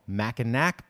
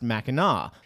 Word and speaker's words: Mackinac: It is also pronounced Mackinac. Now, to add to the weirdness, Mackinac Bridge does have one end in Mackinac City. And yes, that is spelled with a W. Maybe they should pronounce Mackinac City as Mackinac.